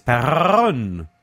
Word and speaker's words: Perron: My name is Keith, K-E-I-T-H. And the last name is Perron. Yes, P-E-R-R-O-N.